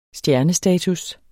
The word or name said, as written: stjernestatus